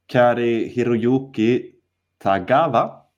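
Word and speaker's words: Cary-Hiroyuki Tagawa: Men nu är det då Kari Hiroyuki Tagawa.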